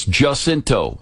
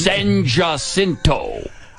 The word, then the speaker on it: San Jacinto: It's Jacinto. This is Houston, Texas. They don't pronounce it the Spanish way. Okay? And they do that on purpose. San Jacinto.